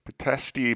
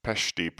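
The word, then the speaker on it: Pitești: Petesti Prison, I think it's pronounced Pesti Prison,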